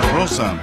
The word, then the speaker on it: rosa: ¿Cómo la goza? La rosa, pronuncia bien la R.